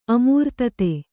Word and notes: ಅಮೃತೋದ: amřtōda

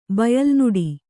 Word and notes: ಬಯಲ್ನುಡಿ: Kannada

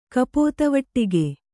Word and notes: ಕಪೋತವಟ್ಟಿಗೆ: kannada

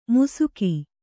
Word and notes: ಮುಸುಕಿ: Kannada

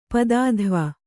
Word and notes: ಪದಾಧ್ವ: padādhva

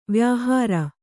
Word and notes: ವ್ಯಾಹಾರ: kannada

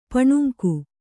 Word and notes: ಪಾನಾಂಗ: Kannada

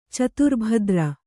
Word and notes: ಚತುರ್ಭದ್ರ: caturbhadra

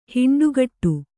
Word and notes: ಹಿಂಡುಗೂಡು: hiṇḍugūḍu

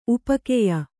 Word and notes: ಉಪ್ಪುಗಾಯಿ: uppugāyi

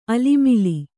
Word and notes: ಆಳಮಾಳ: āḷamāḷa

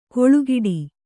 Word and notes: ಕೊಳುಗಿಡಿ: koḷugiḍi

